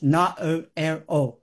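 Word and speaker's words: Naoero: We spell N-A-U-R-U, but it's N-A-O-E-R-O, pronounced, I'll practice this, Na-O-R-O.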